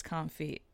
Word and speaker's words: confit: Again, I'm a cheeseburger, and I don't think you pronounce the T. I think it's confit.